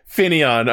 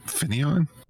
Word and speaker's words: Finneon: That's how you pronounce it? Finneon? – Is it not? It's Finn and Neon.